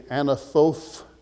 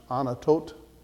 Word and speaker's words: Anathoth: That looks like it would be Anathoth, but I will tell you that the TH hardens and you don't do it we would do a TH. So that's actually to be pronounced Anatot.